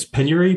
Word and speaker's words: penury: I'm curious as we get started, first of all, it is pronounced, Paneri City? – I think it's penury, but... – Penury. All right. – Yeah. So we'll, penury. It means extreme poverty.